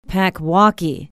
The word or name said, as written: packwaukee